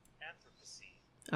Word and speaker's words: Anthropocene: Anthropocene. – All right, enough. – Anthropocene.